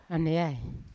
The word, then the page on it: aneai: Yawa